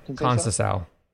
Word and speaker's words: Conceição: Looking at the fight in the back half, I didn't like Conceição. Is that how you say his name? – Conceição. – Conceição.